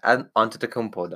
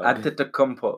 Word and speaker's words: Antetokounmpo: It's going to be Giannis Antetokounmpo. But. – No. Say his name. – Giannis Antetokounmpo. – Where's the L in that? Antetokounmpo, dog. – Antetokounmpo.